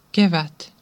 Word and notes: kevät: /ˈke.ʋæt/

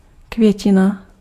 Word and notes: květina: [kvjɛcina]; [kvjɛcɪna]

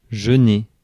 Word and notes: jeûner: France; [ʒø.ne]

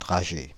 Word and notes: bullet: /ˈbʊl.ɪt/

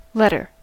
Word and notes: letter: [ˈlɛt.ɚ]; /ˈlɛtə/; [ˈlɛɾɚ]; /ˈlɛt.ə(ɹ)/

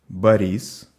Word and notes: Борис: /bɐˈrʲis/

